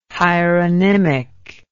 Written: hieronymic

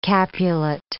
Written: Capulet

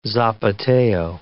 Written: zapateo

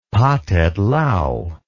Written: Pathet Lao

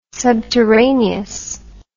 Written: subterraneous

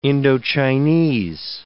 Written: Indo-Chinese

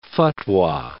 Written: fatwa

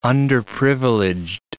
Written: underprivileged

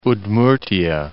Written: Udmurtiya

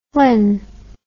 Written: flynn

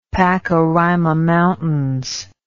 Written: Pacaraima Mountains

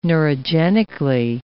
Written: neurogenically

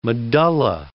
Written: medulla

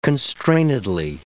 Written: constrainedly

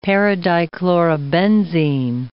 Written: paradichlorobenzene

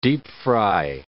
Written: deep-fry

